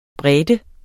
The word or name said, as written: brede